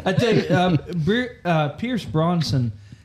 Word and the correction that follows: Brosnan: Brosnan? – Yep.